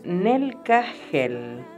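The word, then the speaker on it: Nelcahel: Bueno, tu ángel se llama Nel Cajel y se escribe así, N de nene, E, L, C, A, H, E, L. Cada H se pronuncia como una J suave, Nel Nelcajel es un trono, un ángel de la música.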